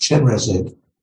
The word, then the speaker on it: Chenrezig: In Tibet, uh, is, is pronounced Chenrezig.